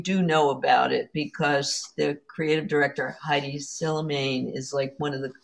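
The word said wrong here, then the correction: Hedi Slimane: Eddie Slimane.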